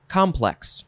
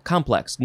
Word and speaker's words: complex: Okay, this one is pronounced complex. Emphasize the first syllable, complex. Noun from 1643. 1. A whole, this is W-H-O-L,